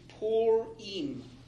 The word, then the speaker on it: Purim: It is Purim. – Purim. – Not Purim. – Okay. – Purim. It's really easy, though. It looks like the word pure, and even my computer sometimes pronounces it that way, but it's Purim.